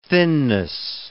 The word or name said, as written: thinness